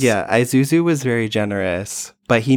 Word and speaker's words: Isuzu: And they were very specific on the pronunciation. Like, they got the brief from the brand. It is Isuzu. – Yeah. – It's not Isuzu. It is Isuzu.